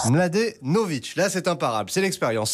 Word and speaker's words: Mladenovic: Kristina Mladenovic, 24 ans, et comme Kristina Mladenovic au début, et ben c'est pas forcément simple à prononcer. Pour que vous puissiez être à l'aise avec son patronyme pour briller à la machine à café tout à l'heure, je vous propose quelques techniques. Vous avez par exemple la méthode Laurent Delahousse sur France 2 qui lui assure plat du pied sécurité, vous décomposez bien les syllabes.